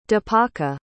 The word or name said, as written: dipaka